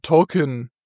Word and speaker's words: Tolkien: Wir sagen Tolkien, im Original wird halt Tolkien ausgesprochen. – Mhm. – Und das klingt halt fast wie Token.